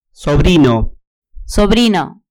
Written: sobrino

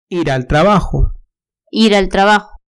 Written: ir al trabajo

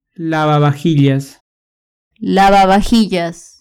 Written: lavavajillas

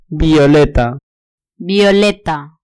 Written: violeta

